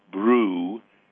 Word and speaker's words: bruschetta: It's brew, as in like beer, bruschetta. And the reason it's schetta, and not, I'm not even going to mispronounce it, is because in Italian, CH is a K sound, not a sh sound, K.